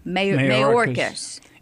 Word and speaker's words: Mayorkas: Well, I was going to ask you, is Mayorkas, is that how you pronounce it? – It looks like the word mayor with K-A-S at the end. – Mayorkas.